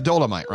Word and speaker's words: Dolomite: It is pronounced Dolomite, right? – Yes.